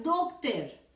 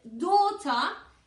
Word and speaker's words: daughter: En la palabra hija, daughter, deja la GH fuera, no digas doctor. Aquí ya lo que yo me propongo más que la GH o lo que sea, estas palabras se escriben con AU o con AW, que no se pronuncian AU, sino O. Esto también tenemos vídeos. Si no me caben por aquí arriba, os los dejo en la caja de descripción, ¿vale? Entonces, Dota, la GH lógicamente te dice que es muda, pero aparte la AU se pronuncia O.